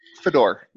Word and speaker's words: Fedor: Fedor. Yes. – Fedor, like Fedora. Cool. And you're, go ahead. – And I was going to say, like the hat.